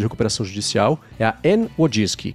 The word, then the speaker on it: Wojcicki: E aí a pronúncia é Wojcicki.